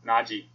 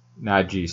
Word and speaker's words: Najee: Worst pick, I'm going to go do you pronounce this Najee Harris? – Najee. – Najee, sorry.